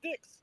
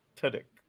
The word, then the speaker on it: Tudyk: We're four dicks. – Oh, jeez. – What, Alan? Two dicks? – That's not how you say his name. – How do you say his name? – Tudyk.